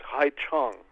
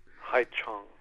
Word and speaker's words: Haiqiong: It's Hai Chong, which is spelled H-A-I-Q-I-O-N-G. – Q-I-O-N-G, pronounced more or less Chung. – Hai Chong.